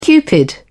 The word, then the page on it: cupid: British English